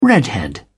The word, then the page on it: redhead: British English